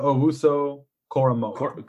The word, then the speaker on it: Owusu-Koramoah: Jeremiah Owusu-Koramoah.